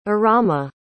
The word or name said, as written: Arama